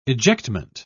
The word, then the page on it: ejectment: British English